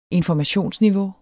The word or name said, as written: informationsniveau